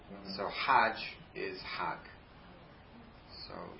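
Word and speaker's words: Hag (حج): You know how to say Hajj in Egyptian Arabic is Hag. Yeah, because they pronounce the Jim like G.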